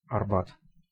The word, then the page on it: Арба́т: [ɐrˈbat]; Russian